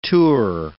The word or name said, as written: tour